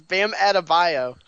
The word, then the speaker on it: Adebayo: And you know, Bam Adebayo. Okay, that's cool. You could say. – Yeah, added like at least 3 syllables there. It's Bam Adebayo.